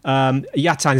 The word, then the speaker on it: Yatagan: It's spelt M-U-R-A-T. That's yeah. Murat. And then Yatagan is actually spelt Y-A-T-A-G-A-N, isn't it? If people are looking for you online. – In Turkish, we don't say the G in the name.